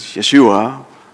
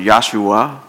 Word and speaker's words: Yeshua: That's what his name means, Yeshua. Some people pronounce it Yahshua.